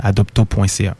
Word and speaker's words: Adopto: Donc directement sur notre site web, vous allez avoir toute l'information là, pour nous contacter, donc à adopto.ca. – Adopto, comme ça se prononce? – Oui, exact. – A-D-O-P-T-O.